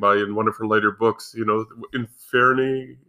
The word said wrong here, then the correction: Inferi: Oh, the Inferi.